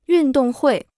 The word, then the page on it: 运动会: yùn dòng huì